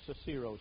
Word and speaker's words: Cicero: Listen to what Cicero wrote. Or Cicero, some pronounce it.